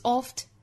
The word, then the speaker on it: oft: The good is, is 复元连读 is oft, is oft, is 跟 oft 可以连读 The good is oft. The good is oft, is oft. 那 oft 这个词它的发音呢其实跟 often 是很像的。我们把后面的 ten 的发音去掉只留前面的 oft, oft 就可以了。